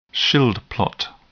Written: Schild plot

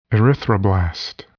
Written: erythroblast

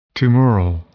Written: tumoral

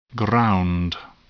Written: ground

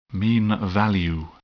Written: mean value

